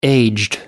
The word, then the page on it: aged: American English